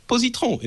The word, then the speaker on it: Positron: You don't say it like this, but it's Positron in French.